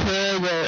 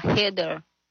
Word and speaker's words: Heather: Su amiga se llama Heather, hermano, Heather. No voy a confundir para decir Heather. Se llama Heather.